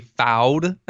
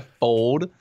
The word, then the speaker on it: fold: He says I say foud, not fold. Come on, man, what are you talking about? I fold.